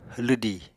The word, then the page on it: lwdì: Apatani